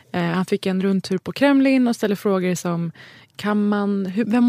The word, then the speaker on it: Kremlin: När han häromdagen var på besök på självaste Kremlin. Nu uttalade jag det som Kremlins. – Ja. – På självaste Kremlin.